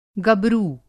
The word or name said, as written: गबरू